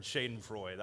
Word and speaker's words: Schadenfreude: It's a German word, Schadenfreude. Now, I'm going to pronounce it in American, Schadenfreude. I like that a little bit better, Schadenfreude.